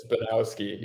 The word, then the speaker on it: Bujanowski: No, the J is silent. So people have that J. – Interesting. Okay. – Yeah. – Okay. – So it's Bujanowski. Yeah.